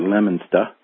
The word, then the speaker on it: Leominster: And if you're from the East Coast, you pronounce it Leominster. However, I'm from here, so a little different pronunciation, but that's where they're at.